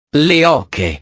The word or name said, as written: le oche